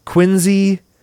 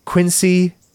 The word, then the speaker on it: Quincy: Anyway, Bill Rosenberg opened his first donut shop in 1948 in Quincy, Mass., which is pronounced like a Z, Quincy, not Quincy.